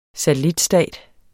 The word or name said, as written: SATELLITSTATER